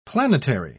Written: planetary

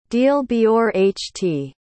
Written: dealbeorht

